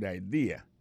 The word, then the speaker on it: idea: T-H-A-T, apostrophe S, good, G-O-O-D pour good, ça veut dire bon, bonne, idea, c'est I-D-E-A, ça veut dire idée, that's a good idea. Alors, accent vient tomber dans la deuxième syllabe là, one idea. Alors, moi, c'est idée, vous faites le fait trois syllabes vraiment, I-D-A.